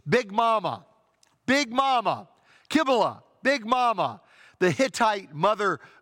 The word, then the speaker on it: Cybele: Her name here, Cybele, is pronounced Kybele.